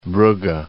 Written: Brugge

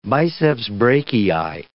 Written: biceps brachii

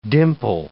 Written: dimple

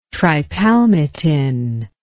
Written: tripalmitin

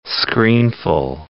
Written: screenful

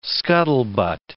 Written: scuttlebutt

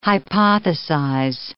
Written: hypothesize

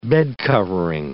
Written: bedcovering

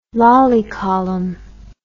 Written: Lally column

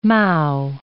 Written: mow

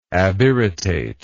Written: abirritative

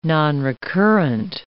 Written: nonrecurrent

